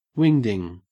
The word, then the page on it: wingding: Australian English